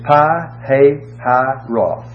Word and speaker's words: Pi-hahiroth: You have to pronounce that in four syllables. It's Pi-hahiroth, between Baal-zephon.